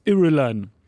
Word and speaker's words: Irulan: Irulan.